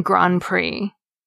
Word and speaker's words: Grand Prix: The same goes for the silent P and silent T in coup d'etat, and the silent D and silent X in Grand Prix.